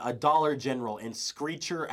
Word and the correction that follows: Screamer: Alabama. – Screamer. – Screamer. I will always Screamer. mispronounce that word.